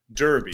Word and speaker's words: Derby: It's really Derby. – Derby. – Right? – Well, it's spelled Derby because we're American, but it's a British term. It's a Britishism, so it's Derby.